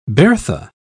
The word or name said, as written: Bertha